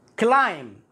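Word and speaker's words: climb: كلايم كلايم البي هنا لا ينطق لا ام اذا جاء بعدها بي في الغالب في الغالب البي لا ينطق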